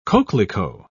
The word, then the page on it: coquelicot: British English